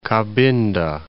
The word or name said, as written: Cabinda